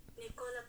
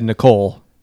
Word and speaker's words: Nikola: Is this how to say it, or? – Nikola Tesla. – Nikola. – I was right. – See, I was right. Yeah. – I said Nikola, but whatever. – All right, well, my apologies. You were right. I was wrong.